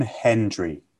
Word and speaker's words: Hendry: It's John Hendry.